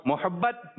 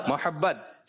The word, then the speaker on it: محبت: اچھا جی کچھ لوگ کہہ رہے ہیں ہمیں مدینہ پاک سے محبت میم کے اوپر پیش اور کچھ کہہ رہے ہیں ہمیں مدینہ پاک سے محبت صحیح ادائیگی صحیح تلفظ یہ ہے